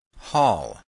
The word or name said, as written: hall